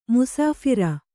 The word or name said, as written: ಮುಸಾಫರ